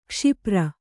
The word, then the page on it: ಕ್ಷಿಪ್ರ: kṣipra